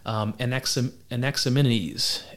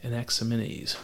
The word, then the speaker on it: Anaximenes: Now we have another guy, similar and an awesome name to pronounce, Anaximenes. Anaximenes. Oh boy. A-N-A-X-I-M-E-N-E-S.